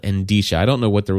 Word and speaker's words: Endicia: It is Endisha. A weird name. It is a weird name. Endisha. No. E-N-D-I. – I want to add syllables in there are. So. – Yeah. E-N-D-I-C-I-A. Endisha.